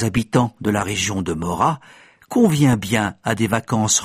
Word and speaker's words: Morat: Mora s'écrit M-O-R-A-T.